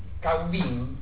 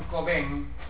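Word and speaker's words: Cauvin: Como forma de enfrentar la revolución neolítica de Child, que es una revolución económica, autores como Cobain Caubín, por favor, cuando vengan a un examen digan Cobain, porque se pronuncia Cobain, no Caubín. Si fuera catalán le diríamos Caubín, pero como es francés se llama Cobain, y es muy fácil decir Cobain, porque en vez de poner la A, pongan A U, pongan una O, es lo mismo.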